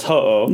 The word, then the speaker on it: Ho-Oh: Ho-Oh and Blossom. – Okay, well, it's Ho-Oh.